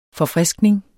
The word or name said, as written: forfriskning